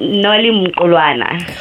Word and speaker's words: Nolly Mkulwana: Nolly, whose last name I'm going to pronounce probably wrong, is Nolly Mukwana. Nolly, is that right, or would you like to correct me? – Uh, it's Nolly Mkulwana definitely a hard pronunciation for me.